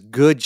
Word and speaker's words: GUD: And speaking of purpose, the name of your bakery is Good Sugar. And if you don't see how it's spelled, you think it's G-O-O-D, but it's G-U with the little thingy on top, D, sugar.